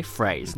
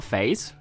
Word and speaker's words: phrase: Phrase, not phase, phrase.